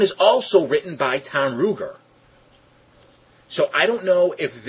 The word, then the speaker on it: Ruegger: That episode is written by a guy by the name of Tom Ruger, I think is how it'd be pronounced, R-U-E-G-G-E-R, would that be Ruger? – Right, I think so.